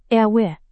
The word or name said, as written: RW